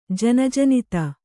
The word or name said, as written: ಜನಜನಿತ